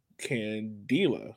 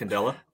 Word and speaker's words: Candela: And I have Mr. Vincenzo Candela. I hope I said it correctly. – I think it's Candela.